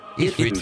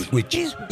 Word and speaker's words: Ipswich: Ipswich. Ipswich.